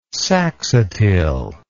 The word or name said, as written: saxatile